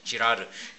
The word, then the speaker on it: 지라르: 르네 지라르, 발음 조심해야 됩니다. 지라르, 예, 아주 유명한 학자예요.